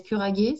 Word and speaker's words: Curage: si tu veux, je fais partie d'une expérimentation qui s'appelle Curaguer, ça s'écrit curage.